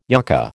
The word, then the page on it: yucca: American English